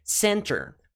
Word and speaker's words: center: Qual que é a sílaba tônica? Sen ou ter? Center, center, center. É o sen, que é a sílaba tônica, tá? Não é center, center. O ter não é a sílaba tônica.